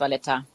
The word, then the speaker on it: Valletta: Hauptstadt Valletta, ist das richtig ausgesprochen? – Richtig, genau, unsere Hauptstadt ist Valletta.